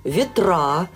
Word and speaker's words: ветра: Вот скажите, пожалуйста, почему моряки ставят ударение не там, где привычнее всем остальным людям? Шторма, они говорят, ветра, говорят. Ну, что еще? Мурманск, классика жанра.